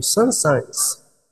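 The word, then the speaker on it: Saint-Saëns: de um autor francês chamado Sansans. O nome dele se escreve S-A-I-N-T, O outro nome S-A-E-N-S, s Sansans em francês, autor da ópera, de outras óperas, mas essa ópera Sansão e Dalila, ah, você pode entrar no YouTube e procurar a área.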